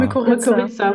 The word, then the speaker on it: Mykorrhiza: Mykorrhiza. – Mykorrhiza.